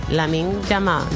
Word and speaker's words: Lamine Yamal: Lamine Yamal. ¿Lo dije bien, Angie? Lamine Yamal. – Sí, que sí.